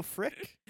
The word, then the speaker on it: Frik: Babu Freak. – Babu Freak. Okay. – Babu Freak. – It wasn't Frick. I thought. – Frick. It's spelled like that, though. – Okay. – Yeah. It is spelled F-R-I-K, because I just found it, too.